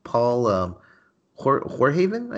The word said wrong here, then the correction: Verhoeven: I think, the guy who did Paul Verhoeven. Yes.